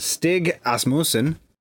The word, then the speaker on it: Stig Asmussen: Directed by, I'm going to say this name wrong because I always say the names wrong, but Stig Asmussen.